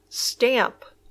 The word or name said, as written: stamp